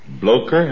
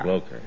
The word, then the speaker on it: Bloecher: I brought a young soprano, Charlotte Bloker. – Blocher? How do you spell it? – That's right. B-L-O-E-C-H-E-R. – Blocher.